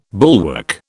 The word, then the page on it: bulwark: British English